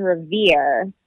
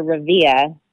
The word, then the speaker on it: Revere: I grew up in Revere, or Revere, because normally we don't pronounce our R's.